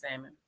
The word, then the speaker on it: salmon: Tell me how it I know you don't eat it because you pronounce the L. It's just salmon.